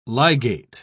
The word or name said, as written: ligate